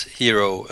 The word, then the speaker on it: Hiero: Yeah, before Glib Designer, there was Hero. It's spelled H-I-E-R-O, yes.